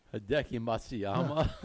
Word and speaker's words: Hideki Matsuyama: And Machirachi, how you pronounce it? – Hideki Matsuyama.